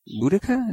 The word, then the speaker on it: Boudica: Boudica? Is that how you pronounce her? – Uh-huh.